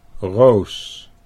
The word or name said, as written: Roos